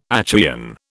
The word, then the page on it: Achaean: British English